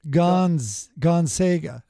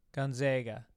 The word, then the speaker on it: Gonzaga: As a former local of Spokane, when people say, also mispronounced, the Jesuit University with a good basketball team is pronounced Gonzaga, second syllable. – Are you doing this on purpose? Gonzaga. – Gonzaga.